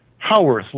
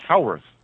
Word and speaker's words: Howarth: Howarth, like Howard with TH on the end.